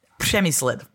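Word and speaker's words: Přemyslid: Przemyslid. So it's a sound that we have in Czech that other people don't have, R with the ha Czech.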